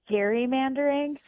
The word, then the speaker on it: gerrymandering: But how do you pronounce the word that is the popular term for redistricting? – Gerrymandering. – You're now my favorite person.